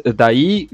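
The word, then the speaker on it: Dai: pues mi nombre es Daí Gómez, eh, se escribe sin acento, se pronuncia con acento, medio, medio extraño.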